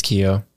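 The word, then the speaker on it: Keough: Riley Cuff? Or is it Keo? – Keo, I think. I think it's Keo.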